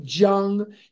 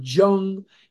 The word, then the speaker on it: Jung: Jung is J-U-N-G. Looks like Jung, and you'll hear people mispronounce it as Jung in meetings or Jung. It's Jung. The J sound does not exist in some languages, and the J is pronounced like a Y. So it is Carl Jung, as if it was Y-U-N-G, just like somebody's name that's here from Sweden.